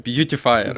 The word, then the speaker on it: Beautifier: Ну, и ты правильно сказал. JS Beautifier.